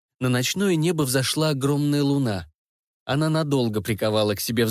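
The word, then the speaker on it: Luna: Она читала заклинание, в паузах которого девушки хором повторяли «Аксилиум Луна». По ударению на первую гласную в слове «Луна» я догадалась, что она читает на латыни.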